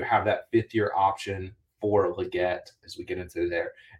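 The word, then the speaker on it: Legette: Again, I'll say both pronunciations because he did say that it is Leggett, but everyone calls him Leggett.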